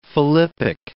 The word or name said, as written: philippic